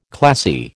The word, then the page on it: classy: American English